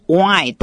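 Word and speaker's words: whine: 所以张博士在最后一段就说，如果你的生命呢只能产生 wine 这个英文单词的发音跟美酒的酒 wine 一样，只多一个 h，但发音是一样的。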